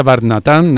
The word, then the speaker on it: Barnatán: Entonces, yo es que ayer estaba ensayando, digo, le decía a mi santa, digo, recuérdame que se pronuncia Barnatán, digo, porque a ver, luego los artistas son muy pejigueros para estas cosas y me...